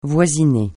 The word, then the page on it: voisiner: francais